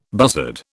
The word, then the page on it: buzzard: British English